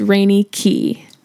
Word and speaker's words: Raineyki: My name on Snapchat is R-A-I-N-E-Y-K-I, pronounced Rainy Key.